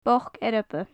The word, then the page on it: boc earba: gaelic